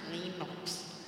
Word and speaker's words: Linux: It's pronounced Linux.